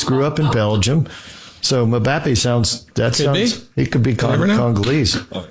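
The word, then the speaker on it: Mbappé: That's Mbappe.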